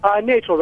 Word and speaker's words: Nachel: Nachel, rhymes with Rachel.